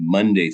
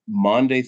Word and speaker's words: Maundy: As you said, Maundy Thursday, and it's spelled M-A-U-N-D-Y. I remember first hearing about it in college, and I couldn't figure out why they were saying Monday Thursday, but it's Maundy Thursday from the Latin word for commandment, mandatum.